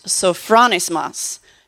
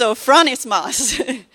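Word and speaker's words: sophronismos (σωφρονισμός): Now, the Greek word for this is pronounced sophronismos. Now, I know that because I pushed the little button that had it say it for me. It's sophronismos.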